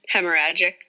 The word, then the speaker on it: hemorrhagic: Hemorrhagic? – Hemorrhagic, which means a bleed.